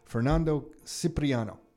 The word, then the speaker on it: Fernando Cipriano: Fernando Cipriano.